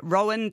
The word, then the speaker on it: Roen: Now, that's spelled R-O-E-N. They spelt it phonetically.